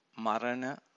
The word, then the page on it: marana: Pali